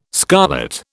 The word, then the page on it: scarlet: British English